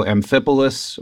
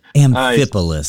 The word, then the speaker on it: Amphipolis: And so usually you'll pronounce the last part polis. And so Amphipolis or Amphipolis doesn't matter. – Amphipolis.